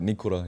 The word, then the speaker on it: Nikora: Is it Nikora? – Yeah. – Nikora? – Like Nikora, yeah.